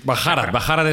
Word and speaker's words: Bharat: Barat. – Bajarat, es como ellos lo pronuncian.